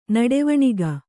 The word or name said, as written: ನಡೆವಣಿಗ